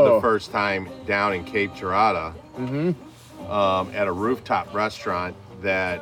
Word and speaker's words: Cape Girardeau: Cape Girardeau.